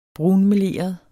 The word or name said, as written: brunmeleret